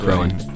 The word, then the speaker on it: Rowan: And it was mixed by Nick Ruffin, who's been Nick Rowan. Nick Rowan. It's Rowan. Oh, I've been pronouncing that wrong for a long time.